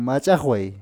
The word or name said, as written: Mach´aqway